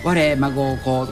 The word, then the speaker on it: 我孫子: そしてラジオ CM でも我孫子をこの 3 世代で暮らせる魅力のある町、はい、そうですね。ちょうど我孫子は我孫子と書いて我孫子と読みますんで、はいはいはい、やっぱり 3世代、にわたって住みやすいと言ってもらえるように今精一杯努力をしています。